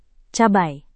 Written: cabai

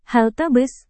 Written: halte bus

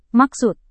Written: maksud

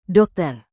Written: dokter

